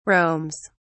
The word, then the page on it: roams: British English